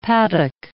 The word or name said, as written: paddock